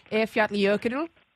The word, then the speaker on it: Eyjafjallajökull: Eyjafjallajökull, is that correct? – Oh, that's not bad at all. Yes, well done.